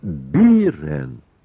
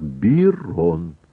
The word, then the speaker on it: Бирон: Лишь вместо Бирен надобно говорить так. Бирон.